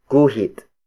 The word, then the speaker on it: guhit: Up next is guhit. Guhit. Guhit. Guhit is spelled as G-U-H-I-T.